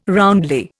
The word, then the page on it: roundly: Indian